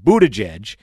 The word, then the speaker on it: Buttigieg: You know, it's pronounced Buttigieg.